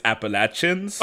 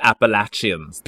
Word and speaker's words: Appalachia: I do want to say, not that anyone who's listening currently would dispute this because they don't know, it is Appalachians. – Oh, is it Appalachians? – People from Appalachia are very particular about the pronunciation. So the way to remember it is, I'll throw an apple at ya. Appalachia.